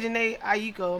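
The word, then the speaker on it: Jhené Aiko: Janae Aiko. – Okay, I say Janae Aiko. I'm saying it wrong. – I think you're saying it right.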